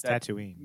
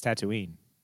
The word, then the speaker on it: Tatooine: It's Tatooine. – Make me have nightmares with, say that again? – You don't know it. It's Tatooine. – Tatooine. I said it wrong.